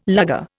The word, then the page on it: Luger: Indian